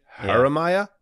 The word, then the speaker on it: Jeremiah: A soft J. Jeremiah.